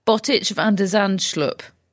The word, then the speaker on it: Botic van der Zandschulp: Straight set versus Botic van der Zandschulp, who I'm very eager becomes a thing.